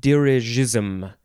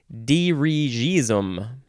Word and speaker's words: dirigism: Dirigism. That seems like the more proper way to say it, opposed to dirigism. Front front. The next word is the first form of dirk, D-I-R-K noun from 1557.